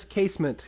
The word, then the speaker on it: Casement: All right, the final question for this week comes from Chris Casement, who sends us a handy pronunciation guide for his last name, saying that it's like basement with a C.